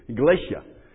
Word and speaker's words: Galatia: Galatia.